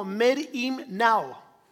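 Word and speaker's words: merimnao (μεριμνάω): La palabra en griego se pronuncia algo así como merimnao.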